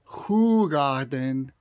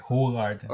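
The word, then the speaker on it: Hoegaarden: Ahorita, para empezar, ya sé pronunciar la marca. Es Hugarden. – Ah, ok, Hugarden.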